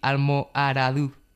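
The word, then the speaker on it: Almoradux: Pues sí, bueno, a mí me han dicho que se pronuncia así, Almoharadú.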